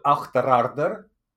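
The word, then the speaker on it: Auchterarder: So instead of getting the train for Achterarder, did I pronounce that all right? – Yeah, not bad. Not bad, yeah.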